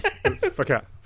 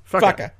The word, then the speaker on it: Fakhar: It's spelled F-A-K-H-A-R. – Oh, fucker. – Yeah, but it's fucker. Fucker.